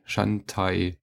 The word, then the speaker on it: Shantae: Shantae. – Also wie Chantalle? – Shantae. Shantae.